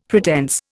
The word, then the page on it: pretense: Indian